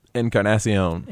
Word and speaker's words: Encarnacion: And the name I was trying to get you to help me pronounce, Edwin... – Encarnacion. – Encarnacion. Okay. – There we go.